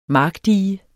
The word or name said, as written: Markdige